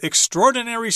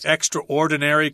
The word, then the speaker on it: extraordinary: And also, they slice those ingredients with extraordinary speed. It's speed you just can't believe. – Really fast. – Extraordinary could also be a pronunciation for that, but most people say extraordinary.